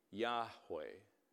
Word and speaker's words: Yahweh: So the personal way to pronounce this, of course, is actually Yahweh.